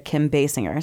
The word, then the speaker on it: Kim Basinger: And the nice man in the YouTube video said Kim Basinger.